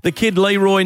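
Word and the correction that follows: The Kid LAROI: Apparently I say that wrong. It's the Kid LaRue, I've been told, by an 11-year-old.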